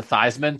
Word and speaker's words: Theismann: And then he changed the pronunciation to Theismann to rhyme with Heisman.